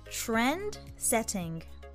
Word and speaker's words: trendsetting: Trendsetting。 Yes, sounds good トレンドの TR の発音がポイントです。